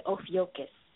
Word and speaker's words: Ophiuchus: I would like to ask, in general, to the brother, the sign Ophiuchus. I don't know if I'm pronouncing it correctly. – You're pronouncing it.